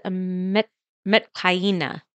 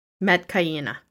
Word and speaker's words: Metkayina: Am I saying that right? – It is Metkayina. – Metkayina. – Metkayina. And they're all Na'vi people.